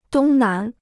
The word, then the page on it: 东南: dōng nán